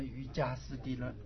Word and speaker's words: 瑜伽: 那我们现在瑜伽师地论要念成瑜伽师地论、啊、根据教育部的规定哈，最近才公布的，这个读音就最近公布，这个读音要读成这个一样啊，所以外面的瑜伽是读得对的啊，反而我们念瑜伽师地论了，这个错了啊、